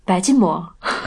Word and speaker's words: 白吉馍: 哦，对，就是白吉馍的发音，那个吉是应该是轻声，所以应该是白吉馍。